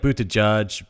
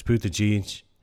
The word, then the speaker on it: Buttigieg: But, so see, it sounds like Buttigieg, but it's Buttigieg.